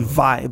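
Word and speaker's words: wai: It's actually vai, but it's spelled W-W-A-I. So kai, K-A-I, is the ocean water.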